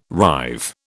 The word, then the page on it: rive: British English